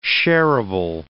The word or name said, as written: sharable